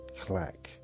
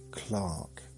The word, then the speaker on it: clerk: People began R dropping in southern England, and clerk came to be pronounced clack. Clack. Should I pronounce that? That's not clerk. Clerk. Clerk. Clark. That's K-L-A-K. Not K-L-A-A-A-A-K. Oh dear. Or if you want to pronounce it phonetically, C-L-A-R-K, like they did earlier.